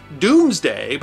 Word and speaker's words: Domesday: And speaking of England's past, William the Conqueror's Doomsday Book, from which we have learned so much about England's past, is pronounced Doomsday, but spelled Domesday, D-O-M-E-S-D-A-Y. Not because the Normans couldn't spell, but because Dome was pronounced Doom before the Great Vowel Shift.